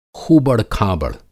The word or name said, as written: ख़ूबड़-खाबड़